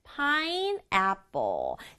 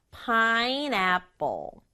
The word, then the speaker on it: pineapple: 우리말은 pineapple, 네 박자지만 영어는 pineapple, 세 박자예요. pineapple, 한 번 더.